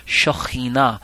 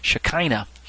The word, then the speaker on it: Shekhinah: Shekhinah. Or as you've probably heard it pronounced in church circles, Shekinah.